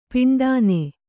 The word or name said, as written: ಪಿಂಡಾನೆ